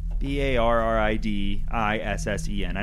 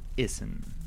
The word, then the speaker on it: Barid Issen: Could you just spell that once more? – And slower. – B A double R I D I double S E N. – That's pronounced Issen? – Barid Issen. – Issen.